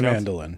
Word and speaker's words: Mandelin: I think it's spelled like the instrument. – I'm pretty sure they're spelled the same way. – M-A-N-D-E-L-I-N.